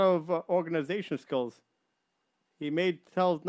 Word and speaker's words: Telz: And his place, I believe it's called, they spell it T-E-L-S-I-A-I, but it's really Telz.